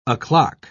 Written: o'clock

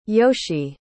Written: yoshi

